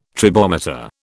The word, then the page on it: tribometer: British English